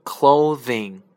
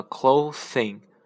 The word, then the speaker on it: clothing: 比如说我们的衣着那个统称的那个 clothing clothing t 呃 c l o c l o t h i n g clothing，千万不要念成了 clothing，是 clothing。